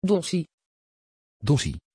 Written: Dottie

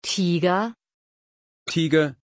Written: Tiger

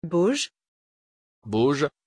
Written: boje

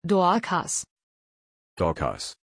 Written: Dorkas

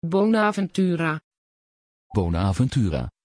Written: bonaventura

